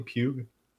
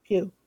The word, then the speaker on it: Pugh: Is that how you say your last name? Pugh? – Yes, yeah. – Pugh.